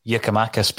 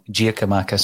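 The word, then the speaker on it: Giakoumakis: I've been yeah. pulled up for my um, pronunciation of Yerkamakis because I keep calling him Giacomakis. So uh, I uh, do take the feedback.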